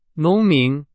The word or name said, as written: 农民